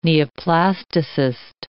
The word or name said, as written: neoplasticist